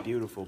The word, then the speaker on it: Biutiful: It's like beautiful, but it's spelled B-I-U-T-I-F-U-L. Like, I guess it's Spanish. – I've never seen a movie. – You should really watch that movie. – B-I-U.